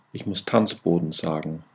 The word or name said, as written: tanzboden